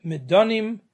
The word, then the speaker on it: Midanim: It is spelled different, it is pronounced differently. Midjanim, Midanim.